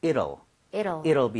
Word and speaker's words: it'll: 이 T 사운드가 약간 플랫이 나요. 그러니까 near 비슷하게 발음이 돼요. – 네. – 그래서 저는 it will 이렇게 하는 것보다는 it'll be over 이런 식으로 이럴 수가 하는 것처럼 이렇게 부드럽게 읽어달라는 거예요.